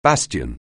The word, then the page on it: bastion: anglais